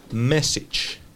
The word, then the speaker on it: message: Y se escribe M-E-S-S-A-G-E. M-E-S-S-A-G-E. Mensaje, que es como masaje, pero con una E al principio. Y se pronuncia message, con message. Una che. Como si dijéramos una che al final, ¿ok? Message.